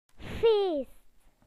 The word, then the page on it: party: British English